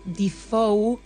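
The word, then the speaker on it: DeFeo: En el 74, Ronald Defoe, creo que se pronuncia, se escribe De Feo, mata a su familia, a sus padres y a sus tres hermanos.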